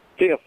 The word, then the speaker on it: Fife: Don Knotts played as a deputy, and he spelled his name F-I-F-E, but they pronounced it Fiff, I believe.